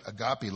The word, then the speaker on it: agape: It's a word, if you want to like anglicize it, it's just pronounce it agape love.